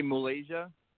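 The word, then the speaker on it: Muhlaysia: But I want to say, do you know how to say her name? – I would say Malaysia. – That's it, Malaysia Booker.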